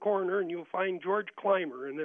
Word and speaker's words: Clymer: Clymer, not Coimer.